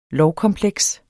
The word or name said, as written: lovkompleks